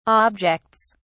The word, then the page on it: objects: British English